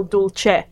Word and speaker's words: dulce: Ecclesial Latin has a soft C. So it'd be, but I think classical Latin has the harder C. Although in that context, it's probably still dulce.